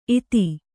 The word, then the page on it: ಇತ್ತು: ittu